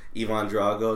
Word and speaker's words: Ivan Drago: And they had just, like, all lined up, a bunch of Apollo Creed, fucking Ivan Drago, is that how you say his name? – Yeah.